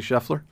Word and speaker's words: Scheffler: I saw, is it Scheffler? Is that how you say it? – Yeah, Scotty Scheffler, the guy who won.